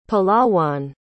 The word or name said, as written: palawan